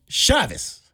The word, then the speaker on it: Chavis: Joy Chavis. Is that how you say her name? – C-H-A-V-I-S.